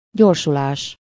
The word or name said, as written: accélération